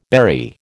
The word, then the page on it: berry: American English